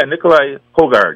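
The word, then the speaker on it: Nicolai Højgaard: His captain's picks, Donald's captain picks, are Tommy Fleetwood, Sepp Straka, Shane Lowry, Justin Rose, Nicholas Huttgard, or is that how it's pronounced? – Yeah, Nikolai Hogard, yeah.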